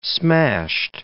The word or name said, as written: smashed